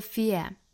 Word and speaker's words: fear: The absence, the absence, 那 absence 和后面的 of 再连读成 absence of, absence of, absence of fear, F-E-A-R, fear, 英音呢这个词是双元音，要读成 ear, ear, 注意不要卷舌，不是 ear, 是 ear,